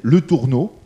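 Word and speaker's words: Létourneau: Et, quand il a travaillé sur ces dossiers, il s'appelle Jocelyn, euh, J-O-C-E-L-Y-N, e Le Tourneau, comme ça se prononce, et le T-O-U-R.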